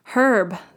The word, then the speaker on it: Herb: Herb, the H is not silent.